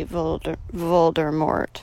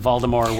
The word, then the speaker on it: Voldemort: Voldemort. The T, Voldemort. – Did you say it like that to close the T? – Yes. – Sorry, I'm of old Harry Potter, where Jim Dale, when he read the initial books, he just pronounced it Voldemort without...